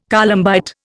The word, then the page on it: columbite: Indian